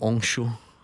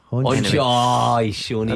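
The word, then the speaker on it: Henchoz: Yeah, I mean, you've butchered the pronunciation Henshaw. Of it, but I'll give you it. – Stefan Henshaw. – Henshaw's. Is it not Onshaw? – Onshaw.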